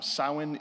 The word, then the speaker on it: Samhain: Now, Samhain is spelled S-A-M-H-A-I-N, but it's pronounced Samhain, and it means summer's end.